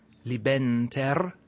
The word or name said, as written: libenter